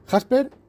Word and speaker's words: Jasper: Bien, Jasper, como suena, ¿vale? Igual que Jasper Hobson en la novela de Julio Verne, pero el Forde son dos Fs juntas, que era Forde, que soy un Forde.